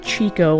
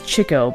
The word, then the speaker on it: Chico: Chico, I'm saying that correctly, it's not Chico like the store your mom shops at. It's Chico because he was a ladies' man, chick.